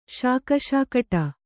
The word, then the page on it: ಶಾಕಶಾಕಟ: śaka śakaṭa